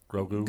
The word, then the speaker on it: Grogu: It starts with a Grogu. – G. – Grogu. – Gugu. – Grogu. – Gugu. – Grogu. – Gaga. – Grogu. – Gugu Gaga. – Murder get him all. – Grogu.